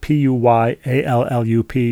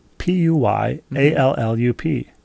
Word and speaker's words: Puyallup: P U P U Y A L L U P. Puyallup. Very good. Puyallup. Puyallup. Pew like P E W like like mm-hmm. someone sitting in their pew.